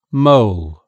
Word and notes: mole: British English

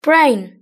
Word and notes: brain: British English

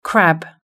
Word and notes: crab: British English

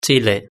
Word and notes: chile: Spain Spanish

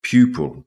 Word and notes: pupil: British English

